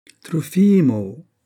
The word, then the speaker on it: Trofimo: « Trofimo ! Trofimo !» La voix appuyait sur l'avant-dernière syllabe et traînait sur la fin.